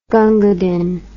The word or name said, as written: Gunga Din